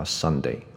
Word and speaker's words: Sunday: OK，啊、呃，比如说这一个字哈，学生也常常念错，啊，这个是礼拜天，那我相信大家都知道这个叫 Sunday。 OK，但是有很多学生他会念成 Someday，Someday，那我再念一次 Sunday 跟 Someday，Sunday，Someday。